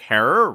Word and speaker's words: Herer: Yeah, actually in two ways, because much like our recent episode about Jack Herer, rhymes with terror.